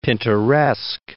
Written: Pinteresque

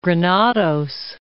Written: Granados

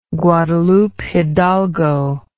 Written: Guadalupe Hidalgo

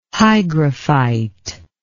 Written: hygrophyte

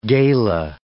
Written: gala

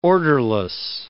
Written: orderless

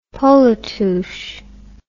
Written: polatouche

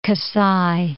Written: Kasai

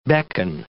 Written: beckon

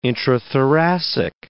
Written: intrathoracic

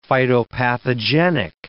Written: phytopathogenic

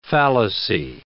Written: fallacy

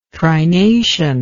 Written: trination

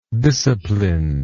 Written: disciplined

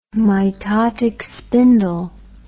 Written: mitotic spindle

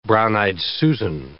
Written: brown-eyed Susan

